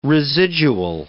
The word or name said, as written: residual